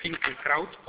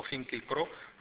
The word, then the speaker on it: Finkielkraut: que es Alain Finkielkraut o Finkielkraut, según lo pronunciemos a la francesa o a la alemana,